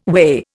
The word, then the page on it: whey: Indian